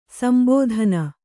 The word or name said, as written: ಸಂಬೋಧನೆ